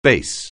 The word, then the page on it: bass: anglais